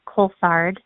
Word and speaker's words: Coulthard: A lot of people really struggle with that last part of the name, Coulthard. But if you think about it as two syllables, coal and thard, that makes it a little bit easier.